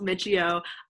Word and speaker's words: Miccio: So my name is Brooke Miccio. I know no one ever knows how to pronounce the last name. It's three syllables, Miccio.